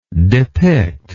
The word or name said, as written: depictive